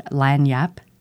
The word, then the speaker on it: lagniappe: Are you familiar with the word lagniapp? It's spelled L-A-G-N-I-A-P-P-E.